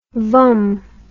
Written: vum

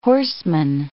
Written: horseman

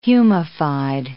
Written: humified

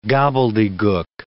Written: gobbledegook

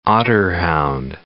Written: otterhound

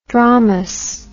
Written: dromous

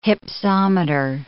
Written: hypsometer

